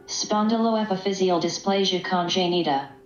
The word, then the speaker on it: Spondyloepiphyseal dysplasia congenita: Spondyloepiphyseal dysplasia congenita.